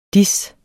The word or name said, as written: dis